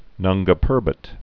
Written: Nanga Parbat

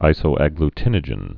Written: isoagglutinogen

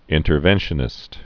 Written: interventionist

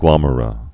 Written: glomera

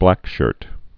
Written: Blackshirt

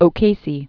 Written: O'Casey

